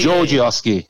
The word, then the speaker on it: Gorgioski: Hey, how do we pronounce his last name? I always go with Gorgioski, but I'm getting it slightly wrong, I believe. Can you clearly... – Gorgioski. – Gorgioski. Oh, I'm so close.